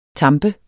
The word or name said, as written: tamp